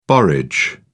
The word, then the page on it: borage: British English